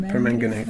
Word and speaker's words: permanganate: I've heard about giving them a dip, an alum, saltwater, bleach, potassium permanganate. Did I say that right? – There's a lot of people who pronounce it that way. That's how I pronounce it, permanganate.